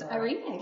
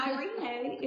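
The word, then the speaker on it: eirene: This, it's Irene. Yeah. No, it's fine. And Irene is spelled E-I-R-E-N-E. E-I-R-E-N-E.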